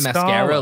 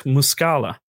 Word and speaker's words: Muscala: Are you saying mascara, like a lady puts like on mascara? – No, it's Mike Muscala. – I know that's the correct way.